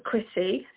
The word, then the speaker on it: Chrissie: It's Chrissy, C-H-R-I-S-S-I-E. – And your surname? – Turner. – And your email address? – Chrissy, spelled the same way, dot Oscar, O-S-C-A-R, at yep.